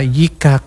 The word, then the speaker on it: Yica: Dan malam ini spesial juga, saya sudah bocorkan tadi, malam ini kita akan ngobrol bareng Yika Club, Yica ya, kalau bacanya Yika, tapi namanya Yica ya.